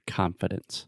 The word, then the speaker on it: confidence: The first sound, that is the ah sound, just like prat, shat, confidence. – Confidence. – At least in American English. That's the way we pronounce it.